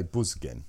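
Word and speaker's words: Büsgen: It's got umlaut, so I would say Busgen.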